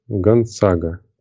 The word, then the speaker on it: Гонзага: Гонзага. Точнее, Гонцага, как пишут, да?